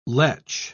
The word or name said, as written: lech